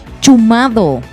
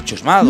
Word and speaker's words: chumado: ¿Prefieres eh, chusmadito o a la mierda ¿cómo se dice? Chumado. – Ah, se dice chusmado.